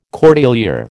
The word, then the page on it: cordelier: American English